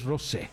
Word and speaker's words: Rosé: Todo el mundo le va a decir Rose, supongo, pero pues tiene acento en la E. Y como tiene acento en la E, para mí es Rosé.